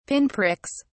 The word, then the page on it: pinpricks: British English